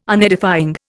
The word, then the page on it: Unedifying: Indian